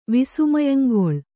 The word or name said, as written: ವಿಸ್ಮಯಂಗೊಳ್